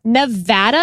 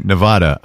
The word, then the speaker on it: Nevada: would say Nevada. – Wrong. – And that is wrong. – Wrong. It is Nevada. And I had to train myself to say Navapple so I could pronounce the A right.